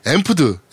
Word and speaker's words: Amped: 하나의 AS만 덧붙이자면 타이탄폴 2에 나오는 거점 정령 모드에 이름이 있는 단어인 앰프드, AMPED. 제가 암패드라 그랬죠? 이거 병신같이. 네. 앰프드라고 읽는 게 맞는 것 같아요.